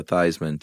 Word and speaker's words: Theismann: The very famous story of quarterback Joe Theismann who changed his spelling or his pronunciation to Theismann to rhyme with Heisman.